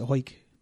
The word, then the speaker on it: Hawick: But people in Hoik pronounce it Hoik.